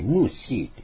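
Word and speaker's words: 牧师: 現在の中国語では、牧師と思って発音しないで、ムーシーって発音するんですね。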